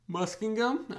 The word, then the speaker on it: Muskingum: Muskingum.